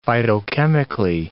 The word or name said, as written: phytochemically